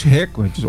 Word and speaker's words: recorde: E o cara veio aqui e entrou pro Guinness, pro livro dos recordes, ou recordes, como a gente teve uma revisão aí das nossas abordagens e entendemos que a palavra mais correta a pronúncia é recorde.